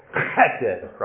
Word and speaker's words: chesed: You want to say it if you're not too close to someone, so it's chesed, right? Chesed. Back of the throat.